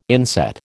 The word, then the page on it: inset: American English